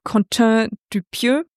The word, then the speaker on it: Quentin Dupieux: Meiner Meinung nach wird der gute Mann ausgesprochen, Quentin Dupieux,